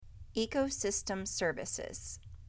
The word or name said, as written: Ecosystem services